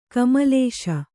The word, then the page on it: ಕೋಮಲಿಸು: kōmalisu